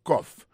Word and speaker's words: cough: I have a cough. Cough la c'est C-O-U-G-H, ça veut dire tous. Ma profite d'utiliser quelques autres mots qui finissent par O-U-G-H, puis il faut la prononcer avec son off.